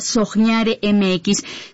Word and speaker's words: Soñare: Síganos en Instagram y TikTok como @soñaremx. Se pronuncia soñare, se escribe con qué, soñaremx.